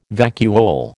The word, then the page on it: vacuole: American English